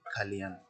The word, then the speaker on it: Calian: C-A-L-I-A-N. – Pronounce it by yourself. – Kalyan.